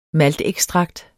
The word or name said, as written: maltekstrakt